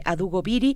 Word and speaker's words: Adugo Biri: Adugo Biri así como lo escuchan, así se escribe Adugo Biri, eh, se trata de la colección Etnopoéticas, los libros digitales que además son de libre acceso.